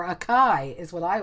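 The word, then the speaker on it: açaí: I don't really know what it tastes like or what it actually is, but it's spelled A-C-A-I. I don't know if there's supposed to be any little accents anywhere. I think it's pronounced acai. – Whoa. It's acai, yeah. You were close.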